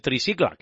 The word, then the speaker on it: Tricicle: pero todos les conocemos como los del tricicle. O el tricicla, que es como se pronuncia más o menos en catalán.